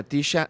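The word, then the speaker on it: TSHA: Así que si les interesa, si, si quieren estar actualizados en el mundo de la electrónica, busquen a Tisha. Se escribe T-S-H-A y ella se llama Tisha Andrews, es de Londres.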